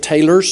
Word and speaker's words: Taylors: Brother Joe comes from Taylors, and that is how you say it, Taylors, South Carolina, where he's been preaching the last 3.5 years.